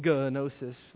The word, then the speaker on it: gnosis: Has a glottal sound at the beginning, gnosis, right? G-N-O-S-I-S. This word to know means that they would have knowledge, gnosis.